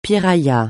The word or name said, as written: piraya